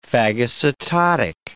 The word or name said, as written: phagocytotic